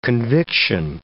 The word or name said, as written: conviction